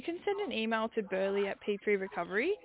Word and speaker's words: Burleigh: Yeah, so it's Burley, B-U-R-L-E-I-G-H. – Okay, Burley.